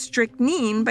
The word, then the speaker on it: strychnine: like arsenic and strychnine, which I learned while researching this episode is sometimes pronounced strychnine by people smarter and classier than I.